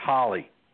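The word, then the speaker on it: Holi: So Veggie Girl, who has been to India recently, has told us to pronounce it correctly is Holly.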